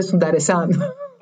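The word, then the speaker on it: Sundaresan: Βίκνε Σουντάρεσαν. – Μπράβο, ναι. Αυτό το τυπάκι που. Μα στέλνει λοιπόν. μήνυμα, λέει και μα λέει με λένε Σουνταρεσάν. – Μπράβο, ναι. Πώ το τονίζει έτσι.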